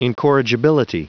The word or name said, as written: incorrigibility